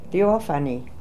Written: Theophany

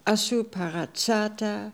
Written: Assooparatsata

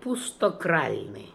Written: Emptykralnian

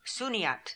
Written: Sooniat

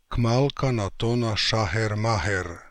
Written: Kmalkanatonashacherm

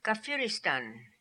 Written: Kafiristan

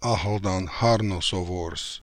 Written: Akhaldanharnosovors

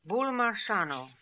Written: Boolmarshano

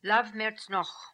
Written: Lav Mertz Nokh

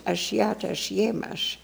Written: Ashiata Shiemash

